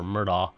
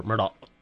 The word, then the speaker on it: Murdaugh: I mean, that's not stretching it beyond, though, to say it's either Murdoch or Murdoff or Murdaugh or Murdaugh. That glottal stop that you couldn't hear.